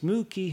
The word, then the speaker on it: Muqui: One of our favorite Twitter followers, Jacqueline Moo. Key, and I believe I'm pronouncing that right. It's Muki, who's at MUQUI24.